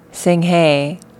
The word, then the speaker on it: Sing Hey: Sing Hey is actually my Korean name, spelt out in a way that white people can say it.